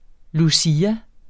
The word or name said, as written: lucia